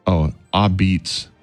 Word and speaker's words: apizza: Calling it a pizza, pronounced, a beets. It looks like A-P-I-Z-Z-A, but it's a beets.